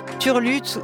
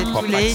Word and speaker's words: Tul8te: Alors, l'artiste qu'on écoute actuellement, ça s'écrit T-U-L-8-T-E, donc Turlute ou Toulate plutôt, comme me propose Antonin Barre. Je crois qu'Antonin Barre a raison, c'est Toulate. – C'est Turlute.